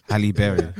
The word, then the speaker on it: Halle Berry: Halle Berry.